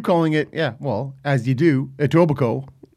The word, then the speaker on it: Etobicoke: And so on the documentary, they keep calling it like Etobicoke. – Yeah. – Like, what is... Say it right, stupid. Right? – Etobicoke. – Yeah. Over and over. Etobicoke.